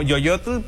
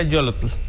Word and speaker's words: Yolotl: Yolotl con doble L. – Sí. – Suena, se lee como Yoyotl, pero se pronuncia Yolotl. – Yolotl.